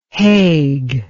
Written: Hague

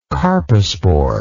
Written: carposporous

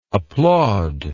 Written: applaudingly

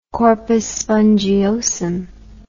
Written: corpus spongiosum

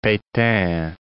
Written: Petain

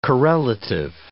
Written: correlative

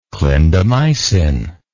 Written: clindamycin

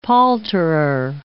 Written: palterer

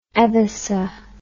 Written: edhessa

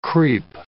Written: creep

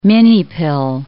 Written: minipill

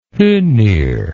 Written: hoenir

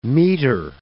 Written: meter

